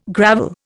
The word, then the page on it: gravel: Indian